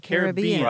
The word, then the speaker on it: Caribbean: Yeah, so you've been using the preferred pronunciation all along, Caribbean.